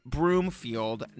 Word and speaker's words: Broomfield: Oh, and yes, I'm now aware that Nick's last name is Broomfield, not Bloomfield. And yes, I cringe and hate myself a little bit more every time I hear myself saying it wrong.